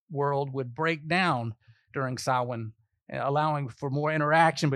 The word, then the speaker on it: Samhain: Looks like Samhain when you see it, but it's an old Gaelic word pronounced Samhain.